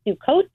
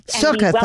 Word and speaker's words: Sukkot: So some people pronounce it sukkot. But that is what I call the Ashkenaz pronunciation, the kind of old Hebrew pronunciation. Oh, it's okay. There's nothing wrong with it. And so if you're pronouncing it with the modern Hebrew accent, it's Sukkot. It's exactly the same word.